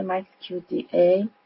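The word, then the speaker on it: MAXQDA: مکس کیو دی ای تلفظ صحیح اسم نرم افزار،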